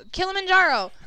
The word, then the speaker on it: Kilimanjaro: Oh, Kilimanjaro.